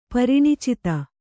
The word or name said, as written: ಪರಿಣಿಚಿತ